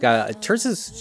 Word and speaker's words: Tirzah: Terza, that's spelled T-I-R-Z-A-H.